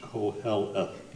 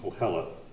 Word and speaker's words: Koheleth: That's pronounced Koheleth, Koheleth.